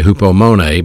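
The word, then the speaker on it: Hupomone (ὑπομονή): The word patience in this verse is a Greek word pronounced hupomeno. Hupomeno. And actually hupomone, but it's from the Greek word meno. Hupomone, I'll spell it for you. H-U-P-O. Hupo. Mone, M-O-N-E.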